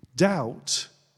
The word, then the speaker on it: doubt: If you've ever wondered why there's a silent B in the word doubt, is that just crazy English spelling? Well, the root of the word doubt is in the word double.